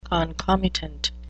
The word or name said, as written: concomitant